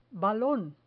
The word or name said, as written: balon